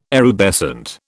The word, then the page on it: erubescent: British English